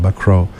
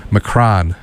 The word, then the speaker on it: Macron: Emmanuel Macron, M-A-C-R-O-N, Macron. In English, someone here would pronounce it probably Macron. But it's Macron.